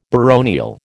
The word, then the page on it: baronial: American English